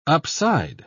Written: upside

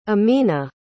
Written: ameena

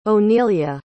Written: onelia

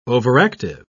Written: overactive

